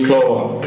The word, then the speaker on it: Clova: C-L-O-V? – Clova.